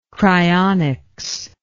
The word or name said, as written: cryonic